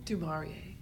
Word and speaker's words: Du Maurier: Du Maurier.